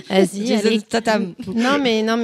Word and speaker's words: Tatanne: José Tatam. Et je dirais même Tatane, pour ceux qui sont portugais.